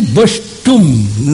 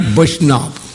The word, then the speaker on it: Vaishnav: They are telling Vaishtum, not Vaishnav, like that.